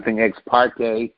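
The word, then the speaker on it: ex parte: Because then all of a sudden you have something ex-partide to apply. – Right, ex-partide. Am I saying it right? Ex-partide? – Something ex-parte outside of counsel.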